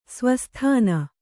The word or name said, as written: ಸ್ವಸ್ಥಾನ